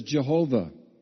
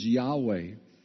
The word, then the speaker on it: Yahweh: The old way we said it was Jehovah. One way that we probably get closer to its original sounding is Yahweh.